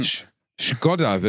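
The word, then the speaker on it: Škoda: Deswegen Sch Skoda wird das ausgesprochen. Bitte nenn mein Auto so, wie es auch heißt.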